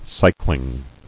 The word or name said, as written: cycling